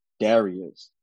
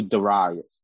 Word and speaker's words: Darius: Sometimes I pronounce the name Darius. Some people do Darius.